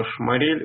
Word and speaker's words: Шморель: Ну, во-первых, я сразу поправлю, фамилия у него Шморель, ударение на последний слог.